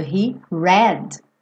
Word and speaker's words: read: Porque read e read, o verbo ler, tanto no presente quanto no passado, se escreve igual, mas o som muda.